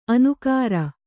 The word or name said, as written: ಅನಕರ